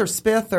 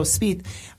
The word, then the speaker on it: Spieth: Is it Spieth or Spith? – I think it's Spieth. – Spieth, thank you. I meant to confirm that before I went on air, but I forgot. So Spieth, he won last year.